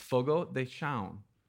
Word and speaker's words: Fogo de Chão: People are like, it's Fogo de Chão. No, it's Fogo de Chão.